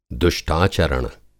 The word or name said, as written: दुष्टाचरण